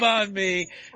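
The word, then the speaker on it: bánh mì: Yeah, and next time I'll get the banh mi. And am I saying that right again? – Yeah, banh mi. That's right.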